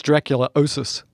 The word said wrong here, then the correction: Dracunculiasis: That's what it sounds like. It's Dracunculiasis.